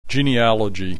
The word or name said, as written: genealogy